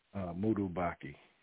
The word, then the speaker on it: Moudou Baqui: Moudou Baqui.